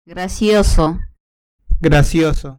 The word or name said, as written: gracioso